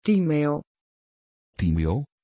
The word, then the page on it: timeo: Dutch